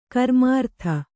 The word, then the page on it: ಕರ್ಮಾರ್ಥ: karmārtha